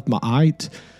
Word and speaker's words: Ma'at: I know in ancient Egyptian mythology, there's the Hall of Ma'at. And I don't know if I'm pronouncing that correctly, but I believe I am.